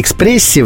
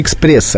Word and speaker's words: экспресс: Вы знаете, вот словари указывают в слове экспресс предпочтительным именно мягкое произношение согласного «р». – Экспрессе. – На экспрессе, да. При том, что экспресс с твердым «р» допустим, этот вариант, он не ошибочен, он допускается, но он стоит на втором месте.